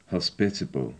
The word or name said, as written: hospitable